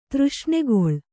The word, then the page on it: ತೃಷ್ಣೆಗೊಳ್: Kannada